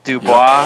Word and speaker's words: Dubois: because he's credited as Kit Dubois. I don't know even how to pronounce that. – Dubois. – Yeah. – French.